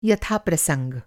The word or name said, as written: प्रसंग